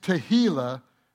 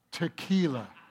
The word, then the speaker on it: Tehillah: Now, to pronounce it, that word correctly, it's Tehillah, not tequila.